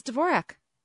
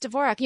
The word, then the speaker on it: Dvorak: So when the announcers announced her name, did they call her Dvorak or Dvorak? – It was Dvorak. It was Dvorak.